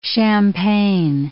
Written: champagne

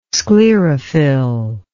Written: sclerophyll

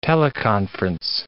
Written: teleconference

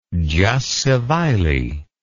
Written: jus civile

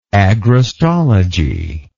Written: agrostology

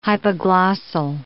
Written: hypoglossal

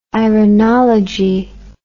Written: irenology

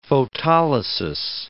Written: photolysis

